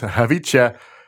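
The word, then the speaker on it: Khvicha: Javice. I'm confident on that one. The K is silent. Javice Kvaratskhelia.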